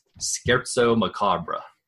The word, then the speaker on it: Scherzo Macabre: It's Scherzo Macabre. – Oh, okay. There all right. – So, that's only because I have that minor in music. So I go, I know how to pronounce that.